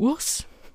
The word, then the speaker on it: WRS: Der Künstler schreibt sich in Großbuchstaben WRS, ausgesprochen wird es aber Urs, denn der Sänger heißt Andrei Ursu, kommt aus Buzău in Rumänien, er ist 29 Jahre alt.